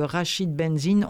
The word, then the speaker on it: Rachid Benzine: Et aujourd'hui, c'était une formidable suggestion que l'homme qui lisait des livres de Rachid Benzine, on a vérifié, c'est bien comme ça qu'il semble le prononcer lui-même.